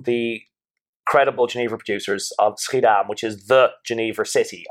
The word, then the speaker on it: Schiedam: Now, can you explain the seal of, and I'm probably going to get the pronunciation of this wrong, but Schiedam? – No, very good.